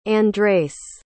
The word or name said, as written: Andreis